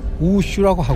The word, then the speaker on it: 우슈: 그러니까 우리가 말할 때무술을 중국식 발음으로 우슈라고 하고 있습니다.